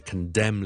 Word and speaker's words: condemn: 咁而一 group 咧，萧叔就成日都听到系有啲人读得麻麻地嘅，就系 silent n 唔读嘅 n 音。最常见嘅咧就系 condemn 呢个字，condemn， condemn 谴责一啲嘢，I condemn this， OK， condemn。